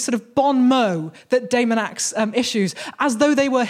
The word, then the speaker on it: Daemonax: And that is a man named Daemonax, which I'm pronouncing like that to try and avoid the fact that it is spelled Demon Axe, like an Amityville horror.